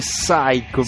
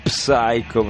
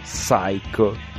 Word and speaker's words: Psycho: Anzi di Psycho Billy, perché non si legge la P. – Ah, è vero, Psycho. – Sì, tutti quelli che vedono i film di Hitchcock dicono Psycho, ma in realtà sarebbe Psycho.